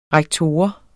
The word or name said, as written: rektor